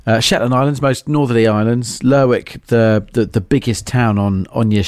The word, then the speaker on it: Lerwick: Is it Lerwick or Lerwick? Or Lerwick, Lerwick, if you're talking Shetland Islands. – Lerwick of Shetland, which I believe is that the northernmost island in Britain? – Correct, yes.